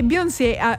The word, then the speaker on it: Beyoncé: È il 2003 e Beyoncé, dai, pronunciamola all'americana, facciamo le fighe.